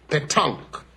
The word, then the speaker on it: pétanque: Oh, no, that's not the correct pronunciation. It's punk. Dear, it's tongue. No, no, no. Please, tongue. The proper pronunciation is pétanque.